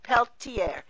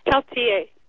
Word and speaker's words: Peltier: Now, you have a story of a young girl, Autumn, I don't know how she pronounces her name. – Peltier. Peltier. – Okay, Peltier.